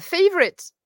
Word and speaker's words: favorite: Euh, un mot, ton mot préféré en anglais pour finir bah, Favorite. En plus, je l'aime bien parce que euh, parce qu'en plus, il, à, l'écrit, il a plusieurs syllabes, favorite, mais euh, à, l'oral, oh, il en a que deux.